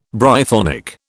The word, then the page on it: Brythonic: British English